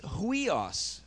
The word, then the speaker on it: huios: The word dito po sa Romans chapter 8, verse 14, for son, it's huios. Okay, so you spell it H-U. I-O-S, huios, okay? And this huios is very different than technon.